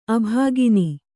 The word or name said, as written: ಅಭಾಗಿನಿ